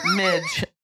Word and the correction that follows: Minch: Not Mitch. Minch. – Minch. – Spell that? – M-I-N-C-H.